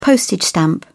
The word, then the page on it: postage stamp: British English